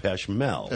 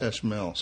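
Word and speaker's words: Pech Merle: It's P E C H M E R L E, like right. – Merle Haggard. But it's pronounced Peshmel. – Peshmel.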